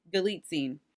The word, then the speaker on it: Galitzine: This one, it also stars Nicholas, how do you say his last name? Nicholas Galitzine. – Galitzine.